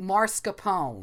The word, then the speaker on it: mascarpone: Mascarpone. – Oh, okay, is what I say. – Sure. – I say like Al Capone with Mars, which is like a lot of other people.